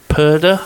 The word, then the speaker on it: Purdah: And we're kind of, because obviously as of next Thursday when Parliament is dissolved, we go into Purdah, I think that's how you pronounce it.